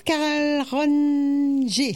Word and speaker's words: Carl Ronger: Bonjour Carl Ronger, c'est comme ça, j'ai pas écorché. – Bonjour, bonjour, Carl Ronger, tout à fait, Carl bonjour Ronger. À toutes vos auditrices.